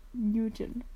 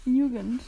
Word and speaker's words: Nugent: Mein Buch der Woche heißt Unraveling Oliver by Liz Nugent. Ich habe schon wieder falsch ausgesprochen. Nugent.